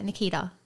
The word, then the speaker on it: Nikita: You really pronounce that T. – Yeah, Nikita. So Nikita? – Yeah, well, everyone is Nikita. – Why? – You don't say the T. Why not? Say it fast. – Nikita. – See, you said the D. – No, you said it was a clear T.